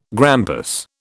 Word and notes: grampus: British English

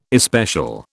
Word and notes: especial: British English

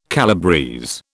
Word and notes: calabrese: British English

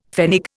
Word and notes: pfennig: Indian

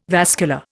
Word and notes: vascular: Indian

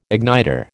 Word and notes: igniter: American English

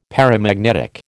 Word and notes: paramagnetic: American English

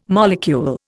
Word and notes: molecule: Indian

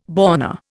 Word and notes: born: Indian

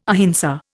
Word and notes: ahimsa: Indian

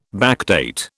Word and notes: backdate: British English

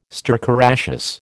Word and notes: stercoraceous: American English

